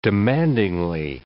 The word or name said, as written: demandingly